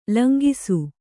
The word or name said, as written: ಲಂಗಿಸು